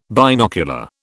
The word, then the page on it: binocular: British English